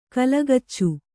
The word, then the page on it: ಕಲಗಚ್ಚು: kannada